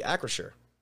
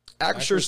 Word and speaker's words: Acrisure: Acrisure. Are we? I probably should check these pronunciations before we just start talking into a microphone. It's Acrisure. Acrisure Stadium.